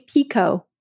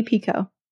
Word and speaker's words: Picoult: Jodi has her own TikTok now, and it's Jodi Pico. So just for everyone to know, we've all been saying her last name wrong. Jodi Pico.